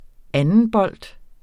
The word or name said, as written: andenbold